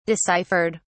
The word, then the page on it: deciphered: British English